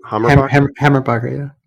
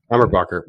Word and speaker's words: Hammerbacher: Hammerbacher. – Hammerbacher.